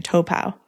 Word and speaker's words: Taupo: We were looking it up because the way that it's spelt, it's Taupo. – Looks like Taupo. – Yeah, but we wanted to know how the locals say it. So we researched that and they were all saying Topaw.